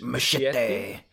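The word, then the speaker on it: Muschietti: Is that how you say his name? – Machete.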